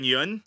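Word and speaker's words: Yeun: Stephen Yoon.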